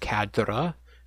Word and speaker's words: cadre: I actually just realized, I found out that it's a French word. It's actually pronounced cadre, so we're butchering it over here. – Just say cadre.